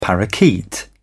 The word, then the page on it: parakeet: British English